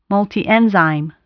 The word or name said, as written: multienzyme